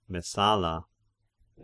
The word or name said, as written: Messala